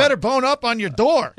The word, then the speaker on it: d'Or: The D'Or is is pronounced door, not day or.